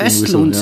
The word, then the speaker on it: Östlund: Ach so, Ruben... Östlund? – Östlund, so, sagt, ja.